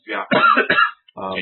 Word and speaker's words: Fiaco: Yeah, and Fiaco, I think it's I worked for the guy. His name was spelled the same way. He pronounced it Fiaco.